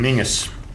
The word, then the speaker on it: Menzies: I think it's a bit like Dalziel and DL, or Menzies and Mingus. Race three at Caulfield on Saturday.